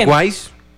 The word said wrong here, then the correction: Always Ready: Always Ready. – ¿Cómo? – Always Ready. – Always Ready.